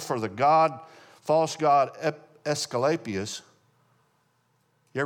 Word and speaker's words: Asclepius: Asclepius is how you pronounce it.